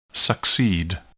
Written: succeed

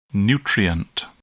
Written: nutrient

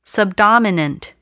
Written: subdominant

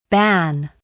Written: ban

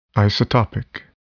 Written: isotopic

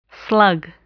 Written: slug

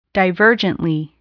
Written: divergently

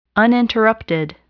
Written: uninterrupted

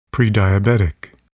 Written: prediabetic

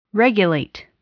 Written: regulate

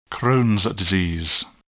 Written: Crohn's disease

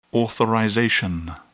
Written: authorization

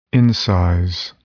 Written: incise

